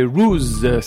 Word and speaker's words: Ruze: On va repartir dans le Digging Records Show avec une nouveauté, c'est Ruse, ça s'écrit R-U-Z-E, ça s'appelle What a Girl Do, extrait de l'album RZ-003, ça vient de Birmingham et c'est pour continuer le Digging Records Show.